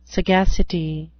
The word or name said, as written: sagacity